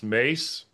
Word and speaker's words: Mies: who my Dutch friends are saying is pronounced Mace, but I kind of like Mies, so I call him Mies.